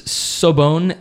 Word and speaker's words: Sobon: Was another California wine, and this was from Amador County, which is northeast in California, and it was Sabon Estates, and it was 2014 vintage. – How do you spell that, please? – It's Sobon, S-O-B-O-N, Estates. And